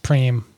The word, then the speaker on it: prime: Everyone has like sort of a prime age. I think I'm going to be, when I'm 45 is when I'll probably be ready to contend for the tour. – It's pronounced preem.